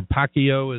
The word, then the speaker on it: Pacquiao: I've got Steve Wilmoth with me, but I've got a little bit on Floyd Mayweather and Manny Pacquiao, or Pacquiao as Rob told me to pronounce it.